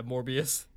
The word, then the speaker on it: Morbius: Yeah, Morbius.